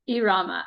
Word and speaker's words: Irama: It's actually Irama. – Ah, see, I'm the white girl trying to speak Spanish. That's what happens. – It's okay. You can't roll your R's. It's Irama. – Irama.